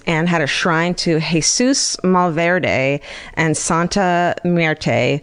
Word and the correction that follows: Santa Muerte: who were, I said that wrong. Listen, I'm Jewish. Don't, I can't. – Santa Muerte. Muerte? Muerte sounds like death, right? – Santa Muerte.